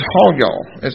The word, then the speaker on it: hayah: The Hebrew word for was is hayah. It's pronounced or spelled H-A-Y-A-H.